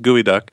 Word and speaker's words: geoduck: GEODUCK. Gooey Duck. – So it's not pronounced Geoduck. – No, it's pronounced Gooey Duck.